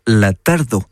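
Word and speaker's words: tardor: Et en catalan, això es diu la tardo. Notez OR à la fin, le R final ne se prononce en pas.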